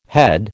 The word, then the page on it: head: American English